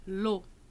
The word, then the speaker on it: eau: Água em francês é o. O. Se escreve E, A, U. Mas se fala o. A água, l'eau.